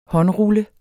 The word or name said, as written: håndrulle